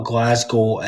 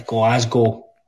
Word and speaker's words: Glasgow: First of all, I'm in Glasgow, or how you say it. – First of all, it's not Glasgow, it's... it's not Glasgow, it's Glasgow.